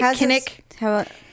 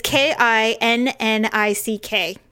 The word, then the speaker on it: Kinnick: The wave happens at the end of the first quarter when fans in attendance at Kinnick Stadium, I hope I'm pronouncing that right, Kinnick. – It's K I N N I C K. Kinnick, Kinnick Stadium.